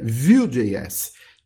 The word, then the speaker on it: Vue.js: Então aprenda aqui conosco que é Vue.js, tá? Que vem de... se pronuncia como se fosse Vue em inglês, MVC, camada Vue, Vue.js.